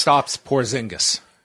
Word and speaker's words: Kristaps Porzingis: Kristaps Porzingis.